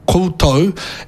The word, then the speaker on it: koutou: Now, for most of Aotearoa, we would spell koutou as K-O-U-T-O-U. Now, the O-U is really heavily pronounced if you're in Waikato or if you're in Taranaki. Anei rā,